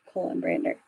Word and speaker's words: Kolenbrander: Am I messing it up? – No, you got it right. It's just how, like how it is spelled, colon brander.